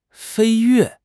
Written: 飞跃

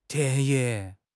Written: 田野

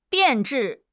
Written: 变质

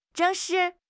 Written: zheng4 shi4

